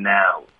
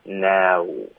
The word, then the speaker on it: now: now，注意我们这个 now 的音，我们在第一句有学它的发音对吧， now 别发 no crying now， out 的特省略导致停顿， crying out loud 的也省略也导致干脆停顿，整个句子就是 she touched my bicep for crying out loud，这句话有点难，OK，大家慢慢的练习。